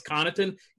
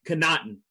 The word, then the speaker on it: Connaughton: They think I can't pronounce Pat Connaughton. I don't care what. I know they say it's Connaughton. Connaughton.